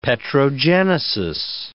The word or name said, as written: petrogenesis